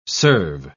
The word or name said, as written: serve